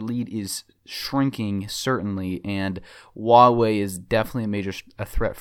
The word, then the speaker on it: Huawei: But what I do want to cover is the competition, particularly Samsung and Huawei is how you pronounce it.